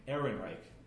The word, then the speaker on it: Ehrenreich: I can't pronounce his last name. I'm sorry. – Ehrenreich. – Thank you.